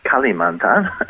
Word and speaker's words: Kalimantan: Most people call it Kalimantan these days, or to pronounce it correctly, Kalimantan.